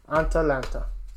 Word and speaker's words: Atalanta: And number five, Atalanta, I believe is how that last one is pronounced. – Atalanta.